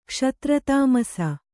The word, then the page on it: ಕ್ಷತ್ರತಾಮಸ: Kannada